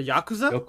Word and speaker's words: Yakuza: Yokuza. There you go. Yokuza. – Yokuza, what's that? – Am I saying that right? Why do I feel like I'm saying it wrong? But the Yakuza? – Yakuza, damn fuck, son of a bitch.